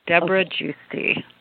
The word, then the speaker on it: Debra Giusti: Am I saying your name properly? – You are. Totally correct. Debra Gusti.